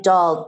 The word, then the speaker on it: Dahl: My name is spelled S-I-M-A. That's S as in Sam, I, M as in Mary, A. Last name Dahl, D as in David, A-H-L, like Roald Dahl, who wrote James and the Giant Peach.